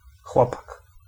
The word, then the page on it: lad: American English; /læd/